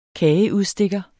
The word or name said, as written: kageudstikker